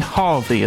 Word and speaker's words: HRVY: And then Harvey, I think you pronounce that, H-R-V-Y.